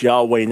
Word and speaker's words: Yahweh: Bibles, older Bibles, Jehovah, normally pronounced Yahweh now,